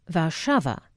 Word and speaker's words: Warszawa: In Polish, it is pronounced Warszawa. Lovely, like the rustle of petticoats in a Viennese waltz.